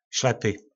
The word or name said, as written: slepy